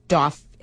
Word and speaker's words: Dauphin: Okay, it's spelled L-A-U-R-E-N-T, but the pronouncer says Laurent Dauphin, and then it's spelled Dauphin, D-A-U-P-H-I-N, but our pronouncer says Dauphin.